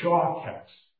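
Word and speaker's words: shaketz (שָׁקַץ): The first word for abomination is Strong's 8263, and it's pronounced sheketz, S-H-E-Q-E-T-S, sheketz. The second Hebrew word is Strong's 8262, and that's shaketz, S-H-A-Q-A-T-S.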